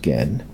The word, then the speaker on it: Ken: That's spelled K-E-N, so it's not Ken, though. It's pronounced Gen.